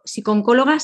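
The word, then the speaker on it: psicooncóloga: Ella es doctora en psicología, psicoterapeuta, eh, colaboradora eh, con, la Fundación de la Caixa, además eres psicóloga, eh, psiconcóloga, se dice, lo he dicho bien, Laura. Sí, correctamente.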